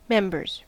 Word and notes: members: American English

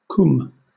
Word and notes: cwm: British English

